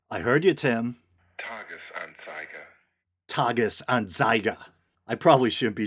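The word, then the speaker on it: Tages-Anzeiger: And he says the name of this Swiss newspaper is pronounced Tagesanzeiger. Tagesanzeiger? Tagesanzeiger.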